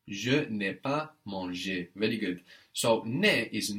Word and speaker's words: mangé: So it sounds the same as the two verb, manger, to eat with the ER ending, and then manger with an E with a written accent, meaning eaten, but sounding exactly like to eat, manger. So, I have eaten. – J'ai mangé. – J'ai mangé. So this manger is spelled M-A-N-G-E, and that E has the accent on the top, the one that goes down from right to left.